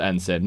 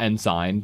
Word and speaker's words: ensign: It is pronounced ensign, not ensign, so...